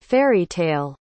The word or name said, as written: fairy-tale